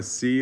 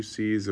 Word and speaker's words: seah: Tomorrow about this time, a sea of fine flour shall be sold for a shekel, and two seas of barley for a shekel at the gate of Samaria." And the captain used a sea as a measuring unit? – Yeah, it's spelled S-E-A-H.